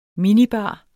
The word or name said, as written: minibar